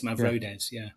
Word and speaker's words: Mavrodes: It's similar to like George Mavrodes, I think that's how you say it. – I always call it Mavrodes, but I think you're right. It's Mavrodes.